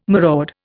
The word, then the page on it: maraud: Indian